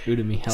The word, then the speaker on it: Udemy: I bought a course off Udemy. I always think of Ubuntu, and my pronunciation goes out the window. – Yep. Udemy.